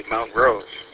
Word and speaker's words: Montrose: The real question is how you would pronounce the city just to the west of it, of Clio, which is Montrose, or if you're from Montrose, you would say Mount Rose, as far as I know. – Garbage. – I just think of the Montrose Jazz Festival every time I drive by that one.